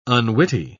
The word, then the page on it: unwitty: British English